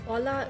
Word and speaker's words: hola: Also, hola ist Hallo. Das H spricht man nicht mit, aber das schreibt man.